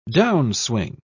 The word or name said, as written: downswing